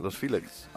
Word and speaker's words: Los Feliz: pero ¿sabes cómo lo ya pronuncian? Los Filex. Ah, bueno, ya. O algo así.